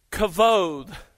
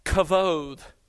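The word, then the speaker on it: kavod (כבוד): it translates a Hebrew word, kavod, is how it's pronounced. It's spelled K-A-B-O-D when it's transliterated into English, but it's pronounced kavod.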